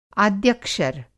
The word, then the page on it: आद्यक्षर: Hindi